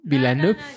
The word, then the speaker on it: Villeneuve: la de la llegada de Denis Villeneuve, o como se pronuncia, donde trata de la llegada, ¿cómo? Villeneuve.